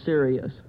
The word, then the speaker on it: Sirius: Cirrus. – Well, you're on the right track. Let's see if we can clear that pronunciation up. – Well, Sirius.